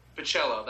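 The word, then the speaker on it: Bachalo: Pichello, that's how you pronounce it.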